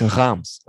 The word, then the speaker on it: Reims: It's Reims.